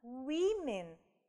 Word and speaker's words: women: Women. Женщины. Несмотря на то, что у нас изменилась только гласная, да, вторая, у нас меняется полностью произношение слова. A woman – женщина, women – женщины.